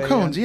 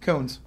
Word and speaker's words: koan: I'm a big fan of the, I don't know if this is the right way to say it, but the Cohen's, Cones, K-O-A-N? – Oh, Cones, yeah. – Is that the right way to say it? – Cones.